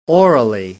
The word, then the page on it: orally: British English